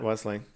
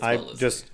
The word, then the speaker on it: Wellesley: Yes, it's not Wesley. It's Wellesley.